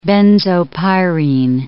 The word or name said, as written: benzopyrene